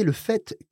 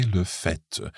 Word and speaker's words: fait: OK, so malgré le fait, despite the fact, now just watch le fait, when le fait, meaning the fact, is a noun, then we pronounce that T. But if you were saying j'ai fait, F-A-I-T, that is a past participle of the verb faire, of course, then we don't pronounce the T unless it's followed by an E or something else that makes the T pronounced.